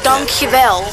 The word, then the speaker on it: dankjewel: Not dankjewel. Dankjewel. Is that right, dankjewel? I'm saying that right, aren't I? – Dankjewel, not donkey. All right.